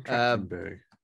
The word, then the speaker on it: Trachtenberg: It's being directed by Dan Trachtenberg, I think. Did I say that right? Yeah. – Dan Trachtenberg.